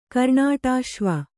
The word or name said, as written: ಕರ್ಣಾಟಾಶ್ವ